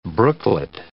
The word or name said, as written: brooklet